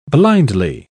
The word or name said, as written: blindly